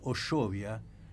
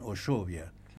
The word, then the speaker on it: Ushuaia: En el acta inicial es Oshovia, no Ushuaia. Ushuaia es como lo escribían los ingleses, porque al escribirlo Ushuaia, ellos pronunciaban Oshovia, claro, que era como se interpretaba de lo que se escuchaban decir a los yámanas.